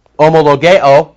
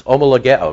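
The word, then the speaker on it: Homologeo (ὁμολογέω): That's kind of a fun word to pronounce. Homologeo. Have you ever tried it? Homologeo. Let's try it. Homologeo. Come on. Homologeo. Not homologeo. I know.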